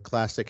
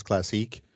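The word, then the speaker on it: Classiq: Is it fair to call what you have, what Classic has, and I'm sorry, I mispronounced it before, as Classique, because it's spelled with a Q, so I thought you were going for that first thing.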